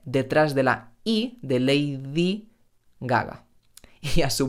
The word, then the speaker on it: Lady Gaga: Gaga, Lady Gaga. En español no decimos Lady Gaga, sino Lady Gaga.